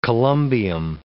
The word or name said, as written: columbium